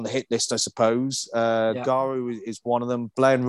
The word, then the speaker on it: Garw: And the other one I was going to mention to you was one in Wales, pronounced Garw, I believe, G-A-R-W.